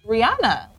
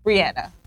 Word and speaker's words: Rihanna: But I had to make sure that I'm saying her name right, because for years, many people like myself would call her Rihanna, when in actuality, it's Rihanna.